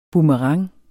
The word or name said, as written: boomerang